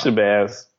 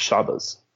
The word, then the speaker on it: Shabazz: It's Shabazz. – Shabazz? – Yeah. – Oh, okay. I wasn't pronouncing it East Coast enough. – If you're, if you speak Arabic, then it's Shabazz.